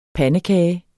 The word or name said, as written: PANDEKAGEN